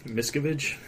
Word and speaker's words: Miscavige: Miscavige.